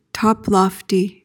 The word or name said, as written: toplofty